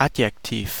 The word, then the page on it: adjective: American English; [ˈædʒ.ɪk.tɪv]